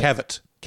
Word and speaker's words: Cavett: And how do you say the guy's last name? Dick Cavett? – Cavett. – Yeah, Cavett.